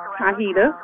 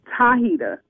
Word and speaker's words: Tahita: Tahita. – Hi-hita. – Yes. – Am I pronouncing that right? Hi-hita? – Tahita with a T. – Oh, Hi-hita. All right, baby. – Tahita.